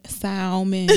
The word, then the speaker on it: salmon: Said because it salmon has an L in it. – Right. It's like a silent L. – I can salmon.